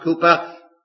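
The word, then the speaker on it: Cowper: As we just sang from Mr. William Cooper, it's pronounced correctly, it's spelt that way, but pronounced Cooper, "God does move in a mysterious way, his wonders to perform."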